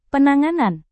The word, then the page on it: penanganan: Indonesian